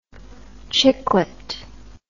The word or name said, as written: chicklet